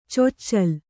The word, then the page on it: ಚೊಚ್ಚಿಲು: coccilu